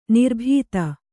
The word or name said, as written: ನಿರಾಬಾಧೆ